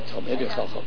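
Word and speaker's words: Talmidei Chachamim: We don't have the brains of these Talmidim Chachamim. – Right, Talmidim Chachamim, correct. – Right, did I say that right? – Right, Talmidim Chachamim.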